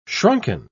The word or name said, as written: shrunken